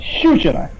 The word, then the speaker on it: щучина: Щучина. Я правильно сделал ударение? – Щучина. Щучина, да. Похоже на Чучина, да.